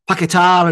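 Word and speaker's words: Paquetá: You know, the one thing about the Brazil game that I did value was I watched something on the internet, online, about the players pronouncing the names. Mm-hmm. So Paqueta is Paqueta. He's not Paqueta. He's Paqueta. He pronounced his own name. Went, I am Lucas Paqueta.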